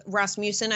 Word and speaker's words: Rasmussen: They conducted a poll with Rasmussen, I believe it's pronounced.